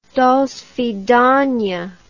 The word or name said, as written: dosvidania